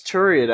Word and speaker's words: Turyt: Is it Turytt? Turyt, I guess? One of them lets Turyt out. Like, I don't know how they... Yeah, it should be Turyt.